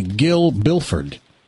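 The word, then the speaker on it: Gil Billford: B-I-L-L-F-O-R-D. – Okay. – There's another G name. That's amazing. – Bill Gilford. – No, Gil Bilford.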